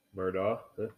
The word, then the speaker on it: Murdaugh: Yeah, well, how would you pronounce that? – Murdoch? – Yeah.